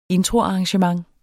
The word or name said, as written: Introarrangement